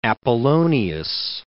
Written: Apollonius

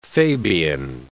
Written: Fabian

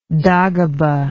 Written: dagoba

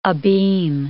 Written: abeam

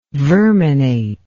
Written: verminate

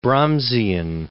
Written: Brahmsian

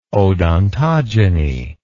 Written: odontogeny

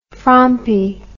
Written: frompy

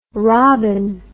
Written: robbin

